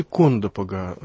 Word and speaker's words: Кондопога: А события, вот ударение правильно, наверняка уже журналисты узнали, Кондопога или Кондопога. – Знаете, по-фински Кондопога.